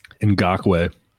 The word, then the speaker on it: Ngakoue: Ngakoue. – Let's say he wants to get in on Ngakoue.